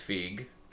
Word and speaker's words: Feige: How do I pronounce his damn last name? – I think it's Feige. – Feige.